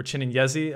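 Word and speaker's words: Chinenyeze: At the middle blocker position, we have Nicolas the Goff, Barthélemy Chininyez, or Chininyezi, as I've heard his name being pronounced by the VNL commentators, and Daryl Boutour.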